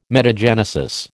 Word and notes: metagenesis: American English

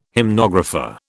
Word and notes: hymnographer: British English

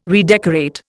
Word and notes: redecorate: Indian

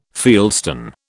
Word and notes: fieldstone: British English

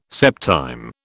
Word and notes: septime: British English

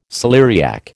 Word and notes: celeriac: American English

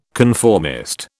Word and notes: conformist: British English

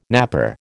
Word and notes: napper: American English

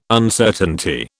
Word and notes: Uncertainty: British English

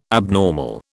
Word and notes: abnormal: British English

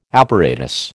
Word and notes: apparatus: American English

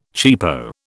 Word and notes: cheapo: British English

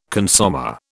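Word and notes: consommé: British English

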